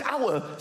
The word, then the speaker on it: hour: Why is the word hour spelt with an H? Why is there an H? The silent H.